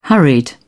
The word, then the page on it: hurried: British English